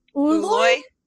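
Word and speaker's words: Ooloi: who is an how do we say that? – Uloy. – Uloy. Uloy. Yeah.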